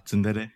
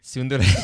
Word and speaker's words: Tsundere: The Tsundere. – Tsundere. Tsundere.